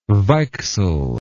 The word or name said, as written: weichsel